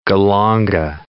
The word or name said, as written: galanga